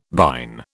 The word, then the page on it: bine: British English